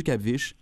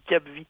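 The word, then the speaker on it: CAPVISH: Directeur du Cap-Viche. – Oui, en mentionnant que ça se dit Cap-Vi. – Ah, ouais. je le sais. – Ça s'écrit Cap-Viche.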